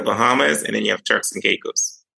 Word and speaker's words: Turks and Caicos: First of all, tell us where the Turk Caicos Islands are and how do you say it? – Oh, that's okay. Where is it located? That's okay. You got it right. It's Turks and Caicos Islands.